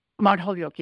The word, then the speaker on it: Mount Holyoke: Am I pronouncing that right? – Mount Holyoke, yes.